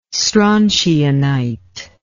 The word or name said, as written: strontianite